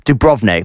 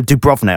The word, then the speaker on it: Dubrovnik: So it's Dubrovnik. There you go. Dubrovnik.